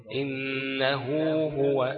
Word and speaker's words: إنه هو: وهي بتتنطق إيه؟ همزة نون هاء واو، صح؟ بقول إيه؟ إنه هو، إنه هو هو، صح كده؟ ففي واو بعد الهاء بتاعت انه، صح؟